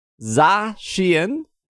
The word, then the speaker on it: Zacian: I think I did Might There's no A at the end of the Zacian. – Oh, Zacian? Sorry. Zacian and Zamazenta.